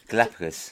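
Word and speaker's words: Galápagos: Well, they have Galapagos. I think I pronounced that right. – Yes, you did. Galapagos.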